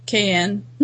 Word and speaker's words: Caen: I think it's Caen, Caen. And it's spelled C-A-E-N.